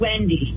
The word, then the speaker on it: Wendy: Rhymes with Ginny. – Did you say Judy? I'm sorry, you're going in and out a little bit. – I'm sorry, it's Wendy.